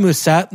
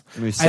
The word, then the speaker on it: Mousset: I'd rather have Mousset, not close, for a million less. – Mousset, I don't think, dude, that's. Don't say that. Don't pronounce that. T is French, dude.